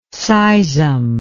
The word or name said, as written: seism